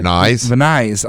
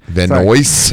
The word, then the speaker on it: Van Nuys: He lives nearby Van Nuys, I think is how you pronounce it. – Van Nuys. – Oh, gosh. – Van Nuys. – I thought it was New.